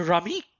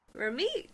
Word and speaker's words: Ramiq: Welcome, my dear friends, to Ramiq. – Ramiq? Wonderful. – How do you spell that? – How do you spell that? – R-A-M-I-Q.